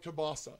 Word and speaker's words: kielbasa: Everyone in Detroit will call it kielbasa. You know, and they'll pronounce the L.